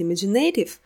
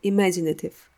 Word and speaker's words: imaginative: хотелось прочитать imaginative, но я узнала, что ударение падает на второй слог — imaginative.